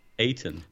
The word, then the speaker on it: Ayrton: Is it Ayrton? – Well, there's Ayrton? A place just near where I live spelt the same as this, and we pronounce it Ayton. – Ayton. – But not to say that that's how you pronounce the name, but we have a village called Ayton, and it's spelt just like that, Ayton.